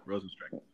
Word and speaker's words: Rozenstruik: So, there you go, Rozenstruik.